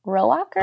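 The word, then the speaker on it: Rohrwacher: I'm going to default to your pronunciation, by Alice Warwicker, you said? – No, Alice. It's Alice Rowacher. – Oh, I'm definitely saying Alice, but I'll say Rowicher.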